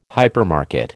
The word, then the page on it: hypermarket: American English